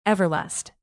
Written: everlast

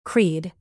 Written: creed

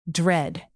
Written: dread

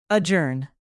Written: adjourn

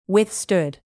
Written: withstood

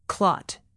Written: clot

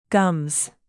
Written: gums

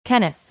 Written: kenneth

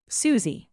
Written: suzie

